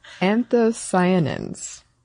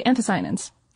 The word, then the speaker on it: anthocyanins: I would say anthocyanins. – Oh, yeah, no, that's totally anthocyanins.